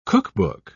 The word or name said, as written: cookbook